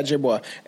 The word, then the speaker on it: Ojibwa: How do you pronounce that? – For the Ojibwa. – That's Ojibwa? – How, yeah, Ojibwa.